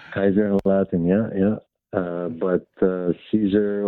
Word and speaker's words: Caesar: So are we going to be pedants and say his name should really be pronounced Kaisar? – Kaisar? I don't know. How do you pronounce it, Caesar? Welcome to Laura Spears Podcast. – Hello, fathers. Thank you for taking my call. Yeah, Caesar. I mean, it depends on the language. Yeah, Caesar or Kaisar in German or whatever.